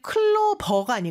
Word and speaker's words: clover: 그리고 clover가 아니고 clover 이렇게 V 발음 주의해서 해주세요.